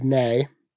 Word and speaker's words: Ney: The town was called Nay. – Nay. – Like N-E-I-G-H? – It was spelled N-E-Y. – Oh my God. – And so no one ever knows how to pronounce it correctly. – Nay.